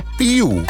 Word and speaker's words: Piu: And this week's Future Friction artist is an artist called Piu. I think it's Piu, P-I-U. Maybe it's just pronounced P-I-U.